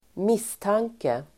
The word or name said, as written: misstanke